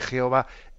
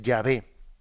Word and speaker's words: Yahvé: Luego los estudios filológicos, eh, vamos, pues tuvieron más, más conciencia, avanzaron más y llegaron a entender que la pronunciación más correcta, más que Jehová, es Yahvé. Yahvé.